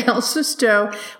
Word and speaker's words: El Susto: Okay, El Susto, which is a documentary I watched the other night.